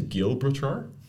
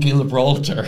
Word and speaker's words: Gibraltar: Moving on, we have Gibraltar. The Rock of Gibraltar. I always want to say Gilbertar.